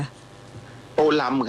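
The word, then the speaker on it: Tô Lâm: อ่านว่าโตเลิมหรือโตลัมคะโตลัมครับผมอยู่ที่เวียดนามเขาเรียกท่านโตลัมครับ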